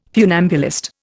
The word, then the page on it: funambulist: Indian